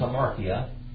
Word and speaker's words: hamartia: The word sin is hamartia. Okay? That's how you spell it in Greek. Hamartia. H-A-M-A-R-T-I-A.